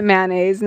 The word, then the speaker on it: mayonnaise: And it's mayonnaise, not and you pronounce it mayonnaise, not mayonnaise.